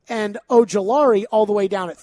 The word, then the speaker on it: Ojulari: And a guy a lot of people have mocked to the Titans, Azeez Ojulari. He's got – I keep mispronouncing that. – Ojulari.